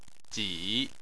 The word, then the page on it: ji: 3